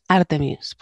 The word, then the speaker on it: Artemis: sino que está leyendo una palabra inglesa y la lee como se pronunciaría en español. – Mm. – Pero amiguitos, Artemis, por favor.